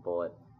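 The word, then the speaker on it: Bulleit: but bullet, depending on how you pronounce it. It's a weird spelling, B-U-L-L-I-E-T, but it a lot of people pronounce it Bullet.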